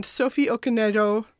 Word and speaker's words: Okonedo: It stars Jesse Buckley, Julie Walters, and Sophie Okonedo.